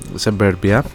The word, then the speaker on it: Suburbia: Το 1900 ε, 1993 ε, κυκλοφόρησε δύο άλμπουμ ε, Black Tie, White Noise και The Buddha of ε, Suburbia, Suburbia να το προφέρουμε πιο σωστά.